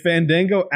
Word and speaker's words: Fandango: No, it's Fandango.